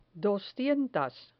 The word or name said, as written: doscientas